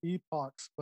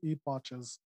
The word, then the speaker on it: epochs: He was like, oh, it's endless epochs, but he kept pronouncing it endless epoches.